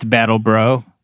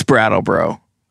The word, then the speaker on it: Brattleboro: You know, I've been calling it Brattleboro the whole time I've lived here. It's Battleboro. – No, it's Brattleboro.